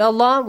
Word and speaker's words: الله: Produce the sound of lam in the word Allah with a light sound. Lightly pronounce it.